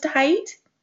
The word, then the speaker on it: Heit: And Angela's your last name pronounced height? – That's right.